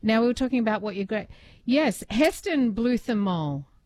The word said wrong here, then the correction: Heston Blumenthal: Heston, yes, Luke, did I absolutely butchered that. – Heston Blumenthal. Yeah.